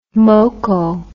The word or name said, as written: moko